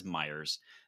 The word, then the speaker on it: Meijer: He says Myers. Shopping at Meijer be okay? – Shopping at Myers will be okay. Myers. Like a true Michigander, and that is right, and you said it correctly.